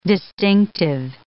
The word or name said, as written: distinctive